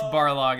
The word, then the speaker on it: Barlog: We're even experiencing this a little bit right now with God of War's Corey Balrog. – No. – No. I just got his name wrong. It's Barlog.